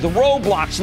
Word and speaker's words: Roblox: And I'm circling back to the online gaming platform with Roblox, and that you pronounce that Ro like R-O-W.